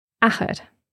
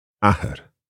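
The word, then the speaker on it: athair: Ahar. – Ahar.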